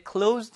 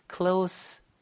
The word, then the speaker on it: close: 가까운이라는 뜻을 가질 때는 close인데, 문좀 닫아주세요 할 때, close the door please 할 때는 그 닫다라는 동사로 쓸 때는 close, sh 발음이 아니라 z 발음이 됩니다. 그래서 close the door please. 이렇게 close라는 발음이 됩니다.